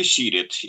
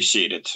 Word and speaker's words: Сирет: Надо сказать, что Кутузова очень многие критиковали за то, что он не добился границы по следующему притоку Дуная по реке Сирет или Серет, в зависимости от произношения.